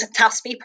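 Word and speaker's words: Teaspaí: So anyway, so like the TLDR is that I've set up an Irish games company, which is called Taspi Press. So it's spelled T-E-A-S-P-A-I, father, with like an accent.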